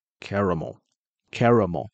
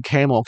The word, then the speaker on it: caramel: caramel，要有个 R 哦，要有一个 R，不然你会念成 camel 是骆驼、哦、 caramel mochiato 嘛，他们不是都会这样念吗？